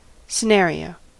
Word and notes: scenario: American English

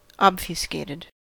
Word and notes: obfuscated: American English

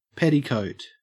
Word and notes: petticoat: Australian English

